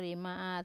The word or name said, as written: rime at